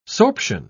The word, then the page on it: sorption: British English